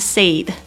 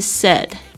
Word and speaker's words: said: He said, 他说，这个 said, 大家特别注意一下它发音哦，不是 said, 是 said. 这个 AI 呢，发这个 eh 的短音，